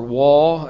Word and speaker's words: waw: You see, this section, these eight verses, begin in the Hebrew language with the letter vav or waw, as is, I think, the common pronunciation.